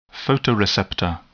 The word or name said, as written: photoreceptor